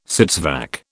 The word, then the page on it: sits vac: British English